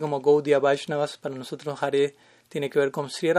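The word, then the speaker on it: Hare: jare generalmente, idealmente se pronuncia como una E, no es jari, es jare, jari es jari.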